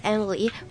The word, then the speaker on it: Emilie: Hello everyone, my name is Emily. While everyone believe my name is spelled like E-M-I-L-Y, but actually my name is E-M-I-L-I-E, and I'm also 16 years old.